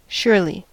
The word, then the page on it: surely: American English